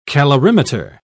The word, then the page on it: calorimeter: British English